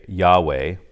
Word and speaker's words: Yahweh: You shall not use the name I am, which in Hebrew we would pronounce today Yahweh.